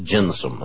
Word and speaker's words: gentium: That's spelled G-E-N-T-I-U-M, but it's pronounced gentium, at least according to John.